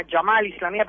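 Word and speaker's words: al-Gama'a al-Islamiyya: En general se llama Gamal Islamía, pero los egipcios lo pronuncian con G, la uh-huh.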